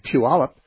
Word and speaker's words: Puyallup: There's a Y in there, but in saying Puyallup, there's no Y in the pronunciation.